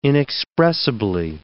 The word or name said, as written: inexpressibly